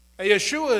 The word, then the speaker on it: Yeshua: I think it's the right way to say it because it puts rightly in my mind who he is and why his name is important. Yeshua is just like Joshua, right?